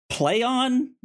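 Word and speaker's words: Plaion: And they announced a rebrand for their company, a new name, which they have gotten out and said it's pronounced Playon.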